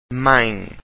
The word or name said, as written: mae